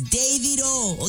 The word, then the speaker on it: Davido: Shout out to Ace of Davido's manager. He'd be like, Sheila, what's all these Davido? It's Davido.